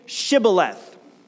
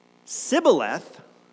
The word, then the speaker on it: Shibboleth: And if he replied, "No," they said, "All right, then say Shibboleth." And if he said Sibboleth, because he could not pronounce the word correctly, they seized him and killed him at the fords of the Jordan.